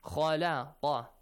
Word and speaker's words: khalaqa: Khalaqa, kha, la, kha. Heavy sound, light sound, heavy sound. Kha, heavy, la, light, kha, heavy.